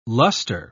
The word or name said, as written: luster